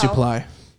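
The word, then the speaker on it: Suplee: Wouldn't that be Supply? – But it's pronounced Supply.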